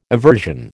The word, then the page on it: aversion: American English